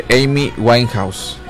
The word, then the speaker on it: Amy Winehouse: Tuve un trabalenguas con su nombre, de Amy Winehouse. Ya lo dije bien.